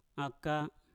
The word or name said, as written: aka